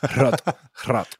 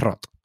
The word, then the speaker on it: HROT: Rot. – H-R-O-T, todo en mayúsculas, Rot.